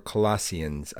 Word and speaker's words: Colossians: We are looking at the books of Philippians and Colossians, or Colossians, I've heard it pronounced as well.